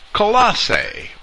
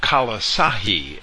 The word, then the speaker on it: Colossae: Colossae, or Colossahi, as I might be tempted to pronounce it, according to the Greek pronunciation guide found in James Strong's Concordance.